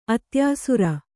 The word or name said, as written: ಅತ್ಯಾಸುರ